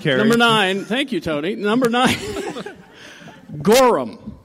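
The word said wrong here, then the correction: gorram: Oh, Goram. – Goram. – You gotta say it right.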